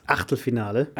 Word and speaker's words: Achtelfinale: Achtelfinale.